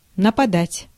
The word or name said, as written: нападать